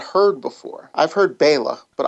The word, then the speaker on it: Bela: Now, I always say Bella, but it is Bela.